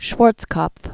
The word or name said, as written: Dame Elisabeth Schwarzkopf